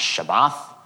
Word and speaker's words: Shabbath: So that word still, that is the Hebrew word. As far as I understand, it's pronounced Shabbath.